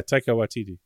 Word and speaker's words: Waititi: Yeah, Taika Waititi.